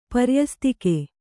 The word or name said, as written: ಪರ್ಯಸ್ತಿಕೆ